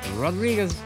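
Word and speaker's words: Rodrigues: Well, it looks like Rodriguez, but it's pronounced something more like Rodrigue.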